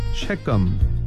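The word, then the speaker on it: Shechem: G-A-A-L Gale. 한 번 더요. Gale. 가알을 Gale 이렇게 발음하시면 됩니다. 그 다음에 세겜은 S-H 쉐겜. 쉐겜 발음은 이렇게 해요. 장소 이름도 있고 사람 이름도 있는 것 같아요. 쉐겜. 세겜을 쉐겜 이렇게 연습해 주시기 바랍니다.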